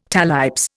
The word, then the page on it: talipes: Indian